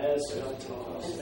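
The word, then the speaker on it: eskatos (ἔσχατος): S, ka, toss. Three syllables. So, as you're learning how to pronounce it, eskatos. So say it comes out eskatos, eskatos. And then you'll say, oh yeah, eskatos. Okay, three syllables.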